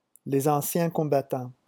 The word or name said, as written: les anciens